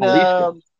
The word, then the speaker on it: Jalisco: Yeah, I mean, I went to a place, Jalisco's, out in Jalisco. Pronounce it with an H, Jalisco.